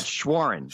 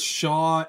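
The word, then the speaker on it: Shawaryn: It's Mike Schwarren. I think that's how you say it. I thought it was Schwarren. – Schwarren. Okay, perfect. I thought it was Shaw, and then I was looking at it differently in terms of the syllables.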